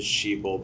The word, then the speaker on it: Xipil: Also, their name is, their name is Sheeple, but it's, like, spelled with an X. – X-I-P-I-L.